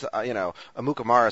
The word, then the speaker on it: Amukamara: And then, of course, Dominique Rogers Cromartie is a great name, and Prince Amukamara is just a fantastic name.